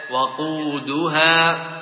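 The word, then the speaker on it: وَقودها: كذلك قوله تعالى يخادعون، يخطف أبصارهم، اعبدوا، تقطع همزة بالضمة عند الابتداء، تقول اعبدوا، وهكذا وقودها، بفتح الواو، ولا تقرأ بضم الواو، ولا يقال وقودها.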